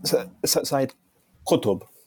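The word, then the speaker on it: Sayyid Qutb: Said Qutb.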